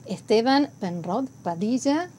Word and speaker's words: Esteban Penrod Padilla: señor Esteban Penrod Padilla.